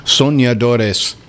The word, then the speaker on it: Soñadores: Los Soñadores. Yeah, that's good. You got a good pronunciation there. Soñadores.